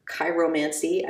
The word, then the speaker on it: chiromancy: It's also known as chiromancy, I believe is how you pronounce it.